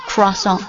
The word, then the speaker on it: croissant: 偏向最后一个发音，就是 Croissant， croissant，你听一听就有这个法语的感觉。 Croissant， croissant， C R O I S S A N T， croissant， croissant，或者 croissant， croissant，或者 croissant， croissant。因为法语当中这个 C R O I 放在一起叫就读作 qua， cro， cro。 Croissant， OI 放在一起通常是发作 I 音的，但是我会偏向最后一个读音 Croissant， Croissant。